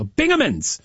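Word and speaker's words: Bingemans: I was told I was pronouncing that incorrectly to start the show. So Bingaman's, it is Big Splash is the area's largest water park that offers days of fun and entertainment for kids of all ages.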